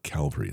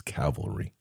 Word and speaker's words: Cavalry: It's not Calvary. It's Cavalry. Fine line difference, but one is Jesus and one is a horse.